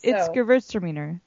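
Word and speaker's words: Gewürztraminer: Gewurztraminer. No, it's not. It's like more than that. There's an extra syllable in there that I can't even pronounce. – It's Gewurztraminer.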